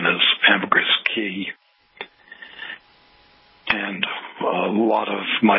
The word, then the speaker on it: Caye: Transportation on the Key, they're spelled C-A-Y-E, it's pronounced Key.